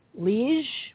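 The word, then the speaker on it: Liège: Now, Michel Poulin was born in Belgium in the 40s, was originally skilled in astrophysics at University of, I actually don't know how to pronounce this, L-I-accent-grave-E-G-E, Liege. That may not be correct, but that's the name of the university that he got this from.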